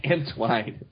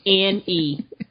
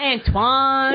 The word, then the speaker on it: Antwine: Antwine. – Yes, that's how it's spelled. Antwine. – Antwine. Why? – Not Antoine.